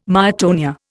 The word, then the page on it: myotonia: Indian